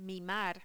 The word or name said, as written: mimar